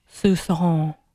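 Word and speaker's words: soupçon: So I had to do one of those, how do you say this? And it's Sousson.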